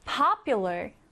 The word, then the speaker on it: popular: popular. popular. 인기 있는인데요. 자, 이 단어에서 강세부터 살펴보면 맨 앞에 이렇게 강세가 들어갑니다. 그래서 popular, popular이라고 발음을 하고요.